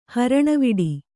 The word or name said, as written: ಹರಣವಿಡಿ